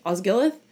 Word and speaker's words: Osgiliath: Denethor wants to hold Osgillith, Osgiliath, since he knows that's where Sauron will hit hard.